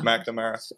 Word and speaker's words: McNamara: McNamara.